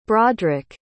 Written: brodrik

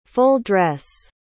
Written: full-dress

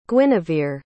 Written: guinevere

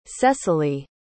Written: cecily

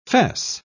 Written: fesse

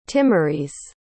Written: Timorese

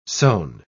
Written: sewn

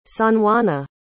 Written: sanjuana

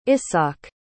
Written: ishaq

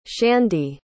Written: shandi

